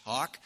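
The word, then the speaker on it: hough: Now, in your King James, it's H-O-U-G-H, but it's pronounced, that's a British word, and it's pronounced hock.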